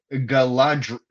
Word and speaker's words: Galadriel: I was going to say Galadriel.